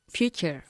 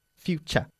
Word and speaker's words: future: Future. – Future. Cha. Como C-H-A. Future. Portugal.